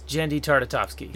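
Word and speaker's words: Genndy Tartakovsky: Yes, Jenny Tartakovsky.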